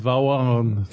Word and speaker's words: Vaughan: No, y no se escribe así, Bond. – Es Vaughn.